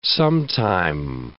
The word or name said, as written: sometime